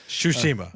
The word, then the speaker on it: Tsushima: kind of similar to how the folks on Ghost of Tsushima, Tsushima, or however I was told to say it in the chat. – Tsushima. – Yeah. – T is silent, bro. – Right. Apparently it is.